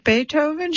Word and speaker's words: Beethoven: Is it Beethoven? She asked once, pronouncing the first part of the composer's name, not Bay, but Bee, like the insect.